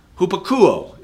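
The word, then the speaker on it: hupakouo: Hupakuo is what it, how you pronounce it, and it means under, to hear.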